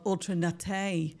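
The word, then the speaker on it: Ultra Naté: Now, I seen a little video with who I would call Ultranate, but she calls herself Ultranate. That is how you pronounce her name.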